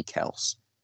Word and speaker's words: Kelce: There's a lot of stuff going around now that the correct spelling or the correct pronunciation of his name might actually be Kels, not Kelce.